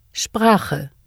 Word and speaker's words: Sprache: The consonant combinations SP and ST are pronounced SP and SHT at the beginning of a word or word stem in German. Listen and repeat. – Sprache.